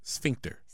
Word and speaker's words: sphincter: Sphincter. – Sphincter. – I promise you that's what it is, right? – Why are you pronouncing it like that? – Right. – Because like Sphinx, but sphincter. – Sphincter. – Sphincter? – S-P-A.